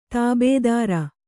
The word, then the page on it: ತಾಬೆದಾರಿ: tābedāri